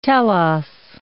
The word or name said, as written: telos